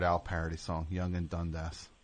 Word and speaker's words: Dundas: No, Dundas is how you say it.